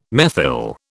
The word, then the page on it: methyl: British English